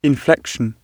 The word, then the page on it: inflection: British English